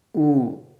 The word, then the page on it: u: sanskrit